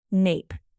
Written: nape

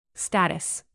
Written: status

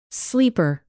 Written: sleeper

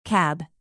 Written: cab

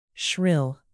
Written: shrill

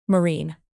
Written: marine

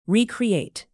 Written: recreate

